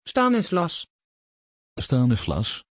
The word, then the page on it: Stanislas: Dutch